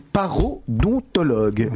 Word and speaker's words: parodontologue: Vous êtes parodontologue. Je le dis bien Parfaitement.